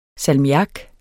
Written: salmiak

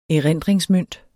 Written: erindringsmønt

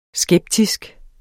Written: skeptisk

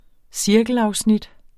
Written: cirkelafsnit

